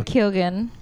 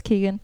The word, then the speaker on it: Keoghan: I thought Barry Keoghan, which I for sure know how to say his name now. I was unsure if it was Keegan.